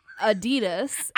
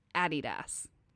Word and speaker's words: Adidas: Adidas, as we call it in America, is the incorrect pronunciation, because it is actually Adidas.